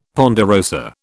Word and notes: ponderosa: British English

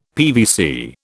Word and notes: PVC: British English

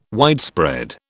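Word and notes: widget: British English

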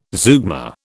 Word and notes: zeugma: British English

